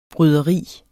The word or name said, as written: bryderi